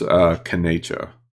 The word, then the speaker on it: kanetsv: It's kanecha. It's spelled K-A-N-E-T-S-V. So E's are pronounced like A's, typically in Cherokee. TSV is pronounced like C-H, cha, like C-H-A.